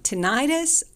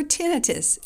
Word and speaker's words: tinnitus: It's called tinnitus or tinnitus. You can say it either way. Both are correct.